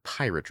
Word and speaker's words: Pyrat: Never, ever, ever buy the pirate rum. That's spelled P-Y-R-A-T.